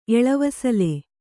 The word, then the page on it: ಎಳವಿಸಿಲ್: eḷavisil